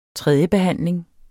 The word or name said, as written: tredjebehandling